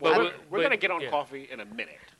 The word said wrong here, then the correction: Kofi: How you say it? – Coffee. – Kofi? – Kofi. – Oh, excuse me, Sonny Sofrito. Kofi. – There we go.